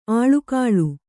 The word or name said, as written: ಆಳಿಗೊಳ್